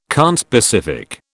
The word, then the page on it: conspecific: British English